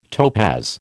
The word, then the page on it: topaz: American English